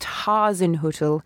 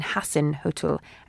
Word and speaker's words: Hasenhüttl: Jackie Oatley tweeted a couple of days ago, friendly reminder, it's pronounced Hasenhüttl rather than Hasenhüttl, as the latter translates to Austrian people as little heart of hate, rather than little hut for hares.